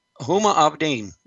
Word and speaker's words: Huma Abedin: The former advisor to Hillary Clinton, when she was former Secretary of State, is Huma Abedin.